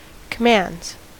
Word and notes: commands: American English

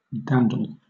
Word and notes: dandle: British English

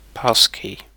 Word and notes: passkey: British English

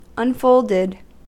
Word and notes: unfolded: American English

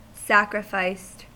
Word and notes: sacrificed: American English

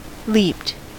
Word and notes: leaped: American English

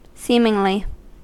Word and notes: seemingly: American English

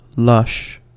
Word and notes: lush: American English

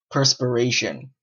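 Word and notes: perspiration: Canadian English

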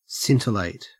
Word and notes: scintillate: Australian English

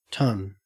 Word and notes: ton: Australian English

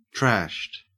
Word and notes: trashed: Australian English

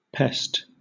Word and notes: pest: British English

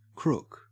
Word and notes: crook: Australian English

